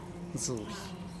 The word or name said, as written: zubee